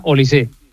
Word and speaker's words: Olise: Lleva acento, pero se pronuncia Olise.